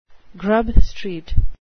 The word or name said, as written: grub street